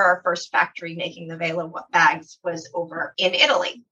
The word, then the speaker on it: Vela: Am I saying that right? Vila? – No. Well, you can say it any way that you want. I will say it's Vela. We pronounce it Vela, and it sounds better when our Italian team says it.